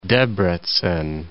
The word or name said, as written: Debrecen